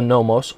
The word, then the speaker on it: nomos: The word that we see in the New Testament that is used to describe the Mosaic Law is nomos, N-O-M-O-S. Pronounced it nomos.